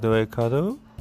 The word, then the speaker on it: Eikado: I used to work part-time for a Japanese sweet shop called Eikado. – Called what? – Called Eikado. – How do you spell that? – It's spelling the E I K A D O. – Oh, Eikado. – Yes, okay. – Eikado. – E-I-K-A-D-O.